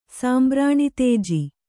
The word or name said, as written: ಸಾಂಬ್ರಾಣಿತೇಜಿ